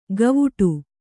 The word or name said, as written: ಗವುಡು